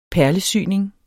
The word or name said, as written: perlesyning